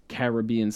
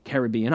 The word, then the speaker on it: Caribbean: By the way, is Caribbean more correct than Caribbean? Caribbean is the older and more ubiquitous pronunciation, but beginning in the 1930s, Caribbean began appearing in dictionaries as an alternate pronunciation, allegedly with British origins.